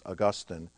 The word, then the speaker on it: Augustine: And his name, of course, is Augustine of Hippo. So I want to spend the rest of my time talking about this man. By the way, the correct pronunciation is Augustine. St. Augustine is in Florida.